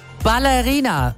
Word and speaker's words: Ballerina: Ballerina mit Betonung auf Baller. Kinoexperte Christoph Bayor aus dem Radio Regenbogen-Team.